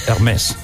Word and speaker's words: Hermès: Perdona, Hermès. – Hermès. Sorry. – Pues ahora frente a los franceses, ahora. – Lo he dicho bien, Hermès.